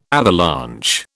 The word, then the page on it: avalanche: British English